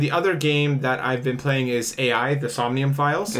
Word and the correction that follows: AI: It's pronounced I.